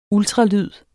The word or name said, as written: ultralyd